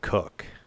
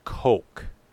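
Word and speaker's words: Koch: The way you pronounce his name isn't Cook. That was just him. It's Coke.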